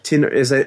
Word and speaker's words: Tinertia: Tinertia. – As in inertia with a T.